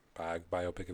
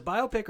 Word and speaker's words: biopic: Is it biopic or biopic? I always wonder. How do you say it? – I've always said biopic, but I like biopic, even though it sounds stupid.